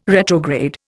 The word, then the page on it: retrograde: Indian